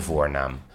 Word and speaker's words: voornaam: Wij leggen de klemtoon deze aflevering op de eerste lettergreep, ah. – de voornaam hoe je iemand noemt.